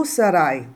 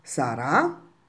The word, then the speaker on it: sarà: Tu sarai dottore, lui sarà parrucchiere. Anche qui l'accento sulla lettera A, quindi si pronuncia sarà e non Sara. Sara è una ragazza, va bene?